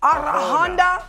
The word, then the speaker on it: Arjona: How you say her name? – Adria. – Arjona.